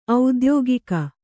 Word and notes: ಔದ್ಯೋಗಿಕ: audyōgika

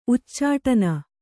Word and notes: ಉಚ್ಚಾಟನ: uccāṭana

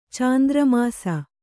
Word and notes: ಚಾಂದ್ರಮಸ: kannada; cāndramasa

